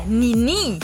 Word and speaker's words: Nini: das ist Nini. Ich hoffe, man spricht das richtig aus. – Oder Nini. Da ist immer die Betonung auf der letzten Silbe.